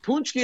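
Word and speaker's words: pączki: P-A-C-Z-K-I. Of course, that's how you spell it.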